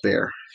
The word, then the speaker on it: Bar: "You know, Barry," she said, "that's the nickname she gave me and my grandparents used for me when I was growing up, often shortened to Bar, pronounced Bear.